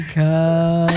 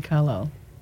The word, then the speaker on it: Carlisle: Is there any Carlisle? – I'm not sure. I don't know how to say the name. – You got it right. Belinda Carlisle.